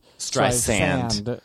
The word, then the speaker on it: Streisand: She likes it to be said Streisand. – She wants the S being pronounced.